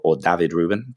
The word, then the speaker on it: David Rubin: But then we also got a cover by David Rubin, David Rubin, to give him his proper Spanish pronunciation.